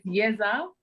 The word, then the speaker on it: Yeza: So it's Yeza.